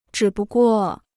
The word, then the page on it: 只不过: zhǐ bu guò